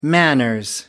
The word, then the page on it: manners: British English